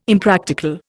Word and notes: Impractical: Indian

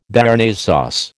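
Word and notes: Béarnaise sauce: American English

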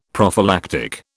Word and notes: prophylactic: British English

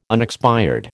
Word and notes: unexpired: American English

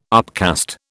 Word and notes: upcast: British English

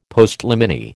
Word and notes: Postliminy: American English

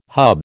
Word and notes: hub: American English

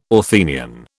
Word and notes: Athenian: British English

